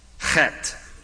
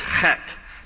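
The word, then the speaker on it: Heth: It is pronounced chet. The H there is pronounced with a guttural ch. Don't turn to your neighbor and try it, or you'll spit all over his or her face. And the TH is actually a hard T, so it's chet. That's how you pronounce that letter.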